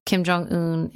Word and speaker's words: Kim Jong Un: Kim Jong Un is.